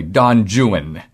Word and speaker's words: Don Juan: Byron wrote a work called Don Juan, but in Byron's day it was actually pronounced literally Don Juan.